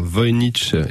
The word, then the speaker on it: Voynich: C'est Hotel Voynich, c'est ça? – Voynich, effectivement. Hotel Voynich.